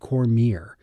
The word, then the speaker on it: Cormier: It was shortly after my chat with Juanita that I discovered that the name of one of the authors we discussed was correctly pronounced Robert Cormier. That's according to his family in Vermont.